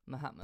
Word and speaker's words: Mahatma: I would normally, like, when I say Mahatma Gandhi, and I think this is a very South English thing. We really emphasise the A. – Mahatma.